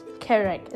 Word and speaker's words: Karak: Henny hat geschrieben, Karak spricht man eigentlich Englisch aus. Karak.